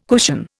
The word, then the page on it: cushion: Indian